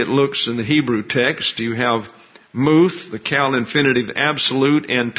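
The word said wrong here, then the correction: moth (מוֹת): Actually, I mispronounced the first one. It's moth, M-O-T-H. That would be a long O in pronunciation.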